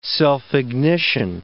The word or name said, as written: self-ignition